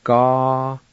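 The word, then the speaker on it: gahatidi: The I with the T is silent in this word. You just pronounce the T sound like this. Ga-ha-t-din.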